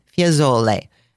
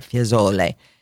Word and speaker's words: Fiesole: Another zona, another neighborhood of Firenze is called Fiesole. And they kind of blur that pronunciation together, Fiesole. It's spelled F-I-E-S-O-L-E.